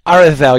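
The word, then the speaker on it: Aredhel: Arathel, yeah. The DH is the voiced the.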